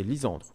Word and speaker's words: Lisandre: Euh, JFC dit Lisandrou avec un accent sur le U. Non, justement, ça c'est sinon ça se prononcerait Lisandrou. Moi c'est Lisandre. Donc l'accent tonique, hein, quand je parle d'accent tonique, c'est un accent à la voix. Il n'y a pas d'accent sur mon prénom. donc il s'appelle L-I-S-A-N-D-R-U.